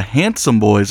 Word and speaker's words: HNDSM Boiz: This is the Handsome Boiz. That's how you say it, right? – Yep, exactly correct.